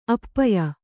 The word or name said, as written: ಅಪೇಯ